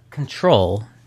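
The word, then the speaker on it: Ctrl: What's it called? Citral? No. – Control. – Control. – It's like how Control is abbreviated on keyboards and stuff. Like the Control key.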